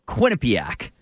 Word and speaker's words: Quinnipiac: Yeah, Quinnipiac.